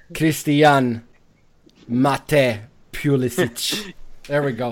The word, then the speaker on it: Pulisic: And, whoa, that was an interesting pronunciation of it. – Well, I want to say Pulisic, even. – Well, that's what he wants you to say. – Yeah. – Yeah, Pulisic.